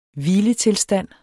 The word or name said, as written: hviletilstand